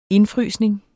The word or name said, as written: indfrysning